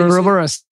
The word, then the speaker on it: Ouroboros: One of those songs being about the snake, the symbol of the snake eating its own tail, Ouroboros, is that how you say it? Ouroboros, yes.